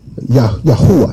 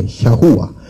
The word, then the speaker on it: Yahuwah: The ancient probably pronounced it Hayua. Yahuwah, excuse me. Yahuwah.